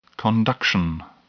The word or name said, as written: conduction